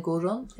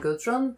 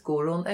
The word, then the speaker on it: Guðrun: The name is difficult to pronounce in English. It's Gudrun and Gudrun. It's spelled with the Faroese letter et, is silent. So internationally, typically people will say Gudrun and Gudrun, but actually it's Gudrun and Gudrun.